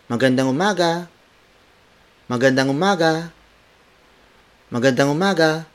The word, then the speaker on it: magandang: It's Magandang umaga.